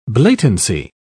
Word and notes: blatancy: British English

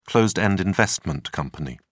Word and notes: closed-end investment company: British English

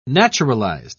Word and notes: naturalized: British English

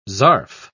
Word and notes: zarf: British English

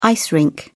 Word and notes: ice rink: British English

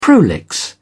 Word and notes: prolix: British English

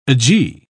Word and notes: agee: British English

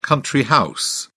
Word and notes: country house: British English

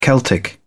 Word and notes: celtic: British English

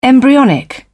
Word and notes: embryonic: British English

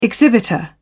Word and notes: exhibitor: British English

